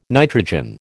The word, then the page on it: nitrogen: American English